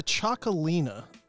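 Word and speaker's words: Txakolina: It's a Chocolina, but it's from northern Spain, so it's spelled not ch, it's a tx.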